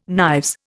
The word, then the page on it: knives: Indian